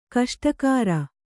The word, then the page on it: ಗೋಷ್ಠಾಗಾರ: gōṣṭhāgāra